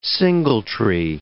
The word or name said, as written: singletree